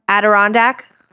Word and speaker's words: Adirondack: Adirondack. – That. – How the fuck did you know? – They have Adirondack chairs, so that's the only way that. – Oh, okay. An Adirondack guide dubbed Typhoid John, presumed to have infected 36 people with 2 deaths.